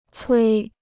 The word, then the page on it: 催: cuī